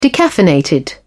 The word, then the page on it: decaffeinated: British English